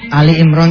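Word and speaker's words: Ali Imran (آل عمران): Ali Imran tuh dibacanya Al Imran, bukan Al Imran. Tapi tapi bukan Al Imran, tapi Ali.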